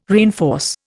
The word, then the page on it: reinforce: Indian